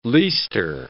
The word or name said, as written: leister